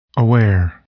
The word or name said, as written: aware